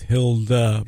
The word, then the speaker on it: Hilde: Hilde or Hilde? – I think it's Hilde. – All right, we'll go with Hilde. – Actually, I think it's Hilda. – Hilda. – I think that's how they pronounce it. Like Broomhilda? – I am embarrassingly non-Scandinavian, so I'm gonna go with Hilda Park in Plymouth. Look, you plug in H-I-L-D-E, you're going to find that particular park.